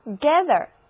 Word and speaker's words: gather: 不过 gather 本身还是一个单词呢，它拼作 G-A-T-H-E-R，注意字母 A 在这里的发音是梅花 A，gather，意思就是聚集、收集、推断的意思。